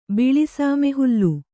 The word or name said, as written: ಬಿಳಿಸಾಮೆಹುಲ್ಲು